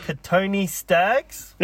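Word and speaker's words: Kotoni Staggs: I want to hear you pronounce him. – Katoni Staggs? – Yeah, good work. – You got it right. Bang on.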